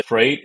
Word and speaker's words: Frayt: So we spell it F-R-A-Y-T. I've seen many who they at times, you know, struggle to pronounce it. And in fact, I also looked up on Google how to pronounce Freight, F-R-E-I-G-H-T. And the spelling that it gave back is exactly how we spell Freight, F-R-A-Y-T.